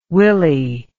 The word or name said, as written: Willey